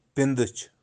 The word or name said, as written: Pindich